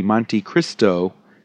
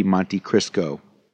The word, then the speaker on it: Monte Cristo: The sandwich is called the Monte Cristo with a T, but quite a lot of people, because it's deep fried, call it the Monte Cristo.